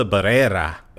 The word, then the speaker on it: Barrera: Melissa Barrera.